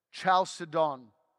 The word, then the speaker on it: Chalcedon: How do I say it? Chalcedon? No, that's Chalcedon. Chalcedon. Let's go with that.